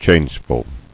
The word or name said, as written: Changeful